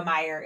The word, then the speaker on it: Meijer: For those that don't have a Meijer, it's Meijer. Mm-hmm. So it's spelled like Majeur, but not how we say it.